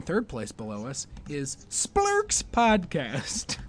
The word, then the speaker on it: Splurch: it's spelled S P L U R C H podcast. Splurks.